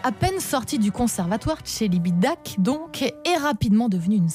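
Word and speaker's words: Celibidache: Alors, que j'ai toujours prononcé Tselibidac, mais qu'il faut prononcer Tselibidac. C'est un événement, c'est la première fois qu'on les entend sur un album et c'est le classique d'Or RTL de juin.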